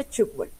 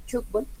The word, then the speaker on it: tugbol: Bueno, primero entonces se pronuncia tugbol. – Ah, vea pues. – Bien. Cuando tengas la oportunidad, el balón cuando rebota contra una malla, contra un marco, hace ese sonido como ch. Entonces por eso se pronuncia y se denomina así, tugbol.